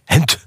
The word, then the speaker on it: Gent: Hent. – Ja. Want Belgen kunnen de G niet uitspreken. Hent.